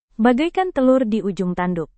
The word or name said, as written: bagaikan telur di ujung tanduk